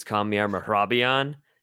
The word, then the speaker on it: Kamiar Mehrabian: So my first and last name are Kamiar Mehrabian. But, like, if you actually pronounced it like the way Persians do, it's Kamiar Mehrabian.